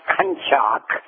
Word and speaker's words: Konschak: His name is Kenneth Butch, in parentheses, B-U-T-C-H. That's his nickname by everybody goes by. And the last name is pronounced Kunchak, K-O-N-S-C-H-A-K.